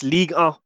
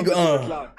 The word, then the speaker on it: Ligue 1: How do you pronounce it? – It's Ligue 1. – Ligue 1. – No, don't. – What? I'm pronouncing it how you told me to pronounce it. – No, Ligue 1.